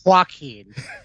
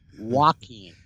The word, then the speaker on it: Joaquin: Not Joaquin. Joaquin.